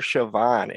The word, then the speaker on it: Chauvin: Well, for one, Andrew, I like the way you pronounce his last name, Chauvin. It's like the French word, Chauvin. I say Chauvin, you say Chauvin.